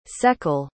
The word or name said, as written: seckel